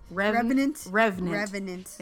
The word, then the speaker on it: revenant: I would have loved to see him as a remnant, because I can't say that right. Remnant. – Revenant.